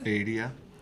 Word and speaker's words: Adia: So, Surfacing featured 4 singles, Building a Mystery, Sweet Surrender, Adia, is that how it's said? – Adia.